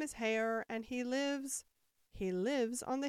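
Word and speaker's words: Haigha: His name is Heyer." – He pronounced it so to rhyme with mayor.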